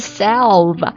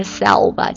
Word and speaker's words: salve: 好，第一个 salve，我比较偏向于这个 salve，其实它两种读音都很常见，salve 或者 salve，看你的这个爱好了。